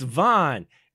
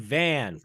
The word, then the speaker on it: Vaughn: It's Vaughn. And in true American fashion, he was saying Van. And I'm like, yeah. No, she pronounced it Vaughn. You can say Vaughn. Just repeat after her. Vaughn.